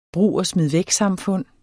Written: brug

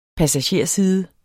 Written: passagerside

